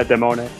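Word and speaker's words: Demoni: how do you pronounce this in Italian? Is it Demone? – Yeah, Demone. Which the I just denotes a plural.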